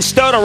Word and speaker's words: Stoto: And, you know, I was talking about this with with Ben Stoto, rhymes with photo.